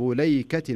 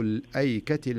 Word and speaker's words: الأيكة: ورش هو الذي يقرأها كذب أصحاب ليكة المرسلين، ورسمت على رواية ورش، لكنها تكتب أو تنطق في رواية حفص الألف تنطق وكأنها مكتوبة كذب أصحاب الأيكة المرسلين، زين؟